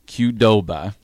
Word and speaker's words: Qdoba: The mini mag includes tons of great discounts from your favorite spots in Lincoln, like Kubota, Alumni Hall. Did I pronounce that wrong? – Qdoba. – Qdoba.